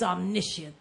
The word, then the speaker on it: omniscience: I like how one Bible dictionary says, a token of his omniscience.